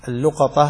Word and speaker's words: اللقطة: اللقطة هكذا تلفظ، وفيها عدة لغات: لقطة ولقطة ولقطة ولقاطة، أربع لغات ذكرها أهل العربية، وجمعها ابن مالك النحوي المشهور في قوله: لقاطة ولقطة ولقطة ولقط ما لاقط قد لقطة، ولقط ما لاقط قد لقطة.